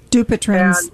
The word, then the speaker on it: Dupuytren's: So actually, my question is, I've started to develop Dupuytren's, I can never pronounce it properly, contracture in my hand. – Dupuytren's.